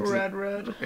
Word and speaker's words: Rudrud: I don't know how to pronounce her last name. – Rudrud.